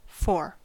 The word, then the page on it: fore: American English